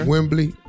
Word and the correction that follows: Wemby: Just call him Wemby, not Wembley. Wemby. That's it.